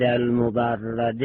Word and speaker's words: المبرد: و منها لام المستغاثه عند المبرد، عند المبرد هم به کسر را جایز خانه بگه هم به فتح را. اونی که ما بیشتر تلفظ می کنیم عند المبرد به فتح را، اخرار ابن خروفین بدون تشدید، خروفین با تشدید را هم استعمال اختیار کرده است.